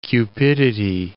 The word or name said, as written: cupidity